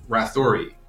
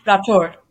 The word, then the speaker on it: Rathore: I am here with Serbi Rathori, and I believe I pronounced that right. I didn't, so. – Rathor. You're close. Rathor. Yeah.